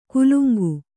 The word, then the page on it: ಕುಲುಂಗು: kannada